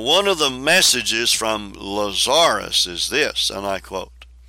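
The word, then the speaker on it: Lazaris: Lazarus, it's spelt like Lazarus, but you put the emphasis on the second symbol.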